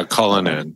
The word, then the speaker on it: Cullinan: But the Rolls Royce, they're they're in some trouble because they have a leased um, 2020 uh, Cullinan is that how you pronounce it? Colin? – Yeah, Colin.